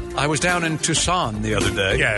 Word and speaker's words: Tucson: Pronounce this city, if you would, T-U-C-S-O-N. – Oh, it's Tucson, of course.